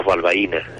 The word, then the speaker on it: Balbaina: ¿Es Balbaina o Balbaina? – Bueno, generalmente decimos Balbaina, sí, con el acento en la I.